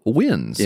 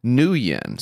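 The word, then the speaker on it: Nguyen: But of course, Peep doesn't say it like wins. He says new yen. So if you were thinking new yen, because that's how Peep says it, it's not right. It's actually pronounced win.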